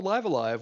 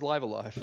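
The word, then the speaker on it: Live A Live: Is it pronounced Live Alive? – It is actually called Live Alive. – Sorry. – Japanese pronunciation. It is Live Alive.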